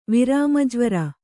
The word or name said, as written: ವಿರಾಮಜ್ವರ